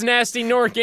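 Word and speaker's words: Gnasty Gnorc: Okay, his name starts with a G, but it shouldn't sound like it starts with a G. – Oh, Gnasty Gnork. – Yeah. – Yeah. – Pronounced Nasty Nork in game, but spelled Gnasty Gnork.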